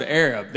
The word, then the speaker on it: Arab: It's spelled A-R-A-B. They don't pronounce it Arab. They pronounce it Arab.